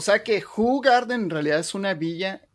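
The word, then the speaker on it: Hoegaarden: Ahorita, para empezar, ya sé pronunciar la marca. Es Hugarden. – Ah, ok, Hugarden.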